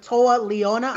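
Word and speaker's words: Toa Liona: In the past, you've teamed with Toa Leona. I didn't butcher his name there. – No.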